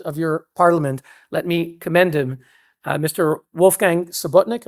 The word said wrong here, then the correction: Sobotka: Am I pronouncing that right? Yeah. Sobotka, who is an amazing person who brought parliamentarians from around the world for a conference, a terrific conference.